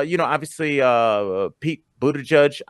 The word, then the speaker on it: Buttigieg: I'm very proud of that pronunciation. I feel like you didn't give me anything for that, but that's okay, Frank. I'm not digging for compliments. I'm just saying I said Pete Buttigieg perfectly. How do you know that's right? Did you look up the pronunciation? Frank, I do my research for the show. – Yeah, I looked okay. it up too.